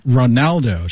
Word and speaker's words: Ronaldo: Ronaldo, 是 R-O-N-A-L-D-O, Ronaldo, Cristiano Ronaldo.